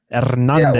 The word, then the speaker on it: Hernandez: Hernandez. – Yeah, the H is silent. And so in the R is more pronounced.